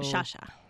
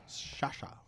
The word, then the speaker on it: Sciascia: Sciascia. – Sciascia.